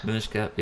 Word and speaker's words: Minish Cap: Minish Cap, yeah.